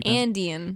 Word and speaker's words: Andean: Indian? – And how do you Andean.